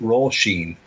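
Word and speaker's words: Róisín: Over the over the O and the second I, and it read, it is pronounced Roisin, which means little rose.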